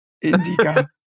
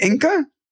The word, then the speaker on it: Indica: Indica. What is it? Inca? How'd you pronounce it? Insica? – Indica.